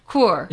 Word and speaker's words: Kuhr: Kuhr. – Yeah. Like Kuhr, like Coors beer, okay. – But without the S.